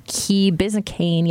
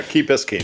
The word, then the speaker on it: Key Biscayne: Police in South Florida are working to determine the identity of a head that washed ashore near Key Biscayne yesterday. I hope I'm saying that right. – Yeah, Key Biscayne.